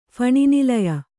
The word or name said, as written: ಫಣಿನಿಲಯ